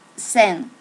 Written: sen

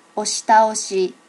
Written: oshi taoshi

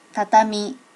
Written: tatami

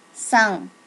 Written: san

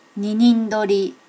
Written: ninin dori

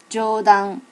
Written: jodan

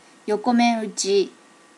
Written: yokomenuchi

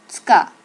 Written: tsuka

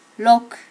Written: roku

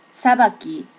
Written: sabaki

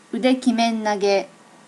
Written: udekimenage

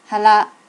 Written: hara

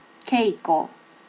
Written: keiko

